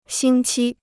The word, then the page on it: 星期: xīng qī